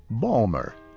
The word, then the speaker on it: Baltimore: Many Baltimore natives pronounce it Balmer or Balmer.